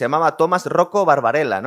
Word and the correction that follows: Barbella: ¿no? Eh, Barbella, sí. – Barbella.